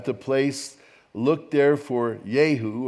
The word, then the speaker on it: Jehu: Now, in the Hebrew, it's pronounced Yehu.